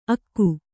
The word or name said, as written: ಅಕ್ಕು